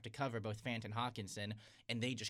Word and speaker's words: Hockenson: Hockenson.